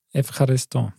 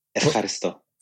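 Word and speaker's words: Evcharisto (Ευχαριστώ): Evcharisto. – Ja. Evcharisto.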